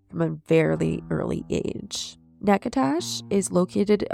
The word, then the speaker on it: Natchitoches: Michael Allen Lacaze was born on July 5th, 1955, just south of Natchitoches, Louisiana. – Is that pronunciation correct? – Yes, I wrote it out phonetically because it is spelled not like that.